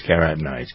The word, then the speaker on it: carotenoids: Yeah, it is carotenoids.